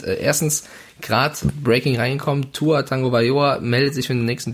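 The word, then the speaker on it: Tua Tagovailoa: Tua Tango Bayoa. Ja, also für alle da draußen, die jetzt sagen, wieso Tango und nicht Tago, äh, Hawaiianer, da gibt's versteckte Ns von ja.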